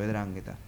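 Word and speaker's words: 'Ndrangheta: Me dijeron que es griego y se pronuncia como E, por eso, Edrangheta.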